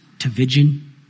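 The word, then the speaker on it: Tchividjian: It rhymes with religion, Tavidian.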